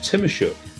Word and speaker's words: Tymoschuk: It's Jeff Tymoschuk. Now, Jeff, I'm hoping I've pronounced that your name correctly. – That's pretty good, actually. It's Tymoschuk, but you're like 98% of the way there.